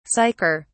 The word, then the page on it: sicker: British English